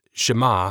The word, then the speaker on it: Shema: This simple declaration by Moses in Deuteronomy 6:4, beginning what is now commonly referred to as the Shema, pronounced Shema, Hebrew for hear, has caused considerable consternation to many who try to understand who and what God is.